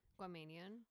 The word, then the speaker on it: Guamanian: Guam? Like Guamanian? – Guamanian.